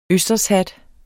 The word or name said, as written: østershat